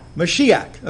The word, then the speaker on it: Mashiach: so it's Moshiach. Is that right, Lord? Mashiach. Okay, Mashiach. They have an A-W in here for some reason. I don't know why. But Mashiach.